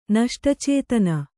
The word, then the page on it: ನಷ್ಟಚೇತನ: Kannada